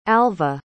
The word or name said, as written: alva